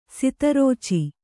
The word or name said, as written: ಸದ್ರುಚಿ